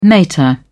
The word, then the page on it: mater: British English